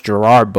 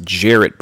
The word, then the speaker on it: Gerard: And fun fact, Gerard Butler, he says his name is not, it's not pronounced Gerard Butler, it's pronounced Jarrett Butler, but he just lets people call him Gerard because everybody just calls with that.